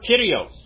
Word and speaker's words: kyrios: And in English, it's brought over from the correct Greek pronunciation. The word kyrios, that means Lord. It's kyrios.